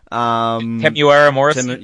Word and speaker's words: Temuera Morrison: And, I can never say his name properly, but Temuera Morrison. – Temuera Morrison. – Yeah, thank you.